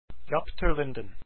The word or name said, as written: jaap